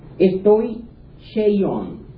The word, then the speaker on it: stoicheion: Esta palabra, algo difícil de pronunciar, pero es S-T-O-I-C-H-E-I-O-N. Estoy Cheion.